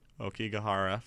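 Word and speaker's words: Aokigahara: Okigahara Forest.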